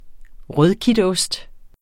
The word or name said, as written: rødkitost